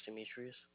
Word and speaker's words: Demetrius: My name is Demetrius. – And spell that for me. – D-E-M-E-T-R-I-U-S. – I-U-S, okay.